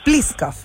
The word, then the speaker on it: Pliskov: Dime cómo se pronuncia tu apellido, por favor. – Pliskov, ya, perfecto. – Tal como suena nomás.